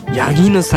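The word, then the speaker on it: 谷絹: でこれなんでかというと、ボーカルのヤギヌマユーさん、谷の絹と書いてヤギヌさんの名前からインスピレーションを受けて付けられた名前なんだそうです。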